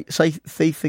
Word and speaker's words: thief: So say thief again. – Thief. – Thief. What you're doing though, the TH you can't pronounce that's at the beginning.